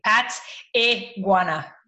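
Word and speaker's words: Pateguana: So Nadia, N-A-D-I-A, and last name Pataguana, P-A-T-E-G-U-A-N-A, P-A-T-E-G-U-A-N-A, Pataguana.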